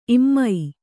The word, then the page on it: ಇಮೆ: kannada